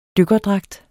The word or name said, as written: dykkerdragt